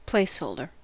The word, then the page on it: placeholder: American English